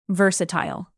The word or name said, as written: versatile